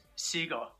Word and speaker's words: Segal: Seagal, but Segal sounds Seagal. A lot cooler, so.